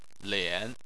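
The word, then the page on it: lian: tone 3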